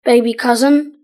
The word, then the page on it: baby cousin: British English